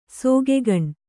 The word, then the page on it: ಸಿಗ್ಗನೆ: siggane